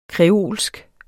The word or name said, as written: kreolsk